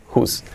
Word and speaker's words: whose: La pronunciación escrita wose no es eh, ni whose ni whose, whose, whose. Así como who, pero simplemente, o sea, quién, who, pero con un S, whose.